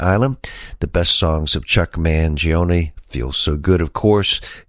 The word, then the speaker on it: Chuck Mangione: And he said, ladies and gentlemen, please welcome Chuck Mangione. So I know I pronounce his name correctly because I heard it come right out of his mouth.